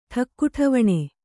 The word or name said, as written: ಠಕ್ಕುಠವಣೆ